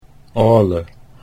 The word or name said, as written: Ale